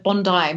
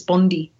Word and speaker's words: Bondi: By the way, nice pronunciation of Bondi. Most Americans think it's Bondi.